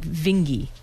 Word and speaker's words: Vinge: We've gotten more feedback this time from Aaron Ferrucci that the way to actually say Werner Vinge's name is it's more like Vinge. – Vinge, like stingy. – Or stingy. Oh, stingy.